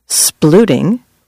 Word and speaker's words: splooting: Splooting, rhymes with hooting, is science, not just amusing animal behavior that's been a recent social media sensation starring corgis.